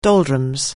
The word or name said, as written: doldrums